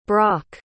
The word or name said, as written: brok